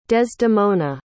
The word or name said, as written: desdemona